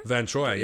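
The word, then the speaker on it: Verne Troyer: Vern Troyer? – Vern Troyer, yeah.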